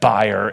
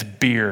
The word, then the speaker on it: bier: Now, I want to pronounce that buyer. And Doug said it's beer, and he told me I have to say it correctly.